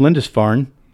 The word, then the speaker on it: Lindisfarne: He is the pastor of St. Cuthbert of Lindisfarne. Did I say that right, Father? – Yes, correct.